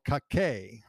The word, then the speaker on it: Cacique: Most indigenous groups lived on a simple subsistence economy and were ruled by a chief called Cacique. I believe that's how you pronounce it, C-A-C-I-Q-U-E, and I'll check on that.